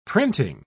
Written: printing

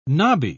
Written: knobby

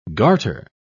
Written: garter

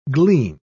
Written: glean

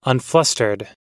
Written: unflustered

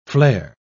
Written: flair

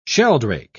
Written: sheldrake

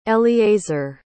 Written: eleazar

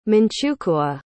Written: Manchukuo

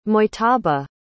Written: mujtaba

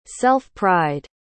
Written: self-pride

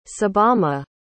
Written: tsubame

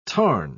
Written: tarn

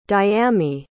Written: Dyami

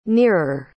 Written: nearer

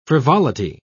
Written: frivolity

